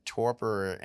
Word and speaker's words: torpor: And there's all the way to, I know years ago I was reading a little bit about torpor, which is that how you pronounce it? Like torpor. – Kind of torpor. – Yep.